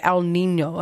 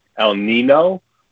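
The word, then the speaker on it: El Niño: No, you're saying it correctly, actually. Some people, I've heard a lot of people say El Nino, which is actually incorrect. If you look at the spelling, there's a tilde over the N, and it's a Spanish word, and that means El Nino.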